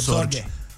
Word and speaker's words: Sorge: Sorgă, sau cum se... Sorge. Richard Sorge.